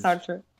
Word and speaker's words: Sartre: Sartre, however you pronounce his name. Sartre. – Sartre. That guy. I think.